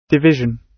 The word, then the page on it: division: British English